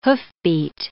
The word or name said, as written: hoofbeat